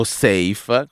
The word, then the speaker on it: Saif: Questa è Elea insieme a Promesse e Saif, o Saif come abbiamo imparato si dovrebbe pronunciare.